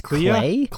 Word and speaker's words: Clea: God, I don't know how to say her first name. C-L-E-A. Is that just Clea? – Clea Duvall.